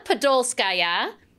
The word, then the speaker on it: Podolskaya: And I am thrilled to be joined today with one of my co conspirators here at Easy Post, Anna Podolskaya.